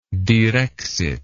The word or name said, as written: direxit